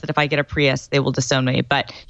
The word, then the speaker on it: Prius: Well, you're going to be in Seattle, so the only two cars that are acceptable are either a Prius or a Tesla. – A what? – Yeah. – A Prius, sorry. I used the Top Gear pronunciation for a second there.